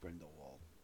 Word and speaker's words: Grindelwald: It's pronounced Grindelwald.